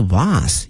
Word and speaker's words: vase: V-A-S-E 哈它有两个发音一个是 vase 一个是 vase 哈都可以哈没有问题哈但是呢它的拼写都是一样的 V-A-S-E 但是发音啊，